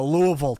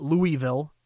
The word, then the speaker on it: Louisville: You're going to show me a Louisville ticket. You hear that from a New Englander, a Yankee up here in New England? Not Louisville, Louisville. I got to say it correctly.